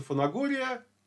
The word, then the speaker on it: Фанагория: то в те времена он назывался Фанагория, согласно греческим правилам чтения, и я не вижу, э, почему бы нам сейчас использовать современное наше произношение кубанское, а не историческое греческое для названия греческого города.